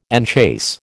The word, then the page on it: enchase: American English